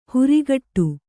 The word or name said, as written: ಹೊರಕಡೆ